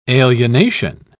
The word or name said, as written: alienation